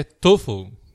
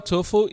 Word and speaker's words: TOEFL: Que é TOEFL. – Pessoal, não é TOEFL, é TOEFL. – Okay. – TOEFL. então vamos lá. É, conta aqui pra gente, agora no início, o que é o TOEFL e para que serve ele.